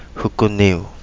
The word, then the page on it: Hukuniu: Kapingamarangi